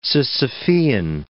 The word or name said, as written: Sisyphean